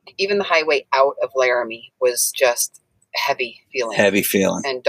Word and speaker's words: Laramie: Laramie.